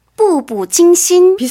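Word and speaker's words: 步步惊心: 발음을 어떻게 하나요, 그러면? – 부부惊心.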